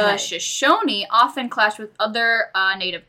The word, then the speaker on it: Shoshone: I'm going to first explain with the Shoshan. I am not pronouncing it right. Let me look it up. – I think you might be. – Shoshone. – Okay.